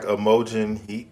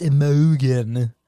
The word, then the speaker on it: Imogen: I thought it was like Imogen Heap. – That's what I thought, too. Like but it, like she's British, so it's like probably like, it's Imogen.